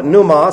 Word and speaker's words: pneuma: Now, for those of you that insist on speaking the Greek language in English, you would call this pneuma. You call it pneuma simply because of the fact that the P and the N in English is silent, but not so in Greek.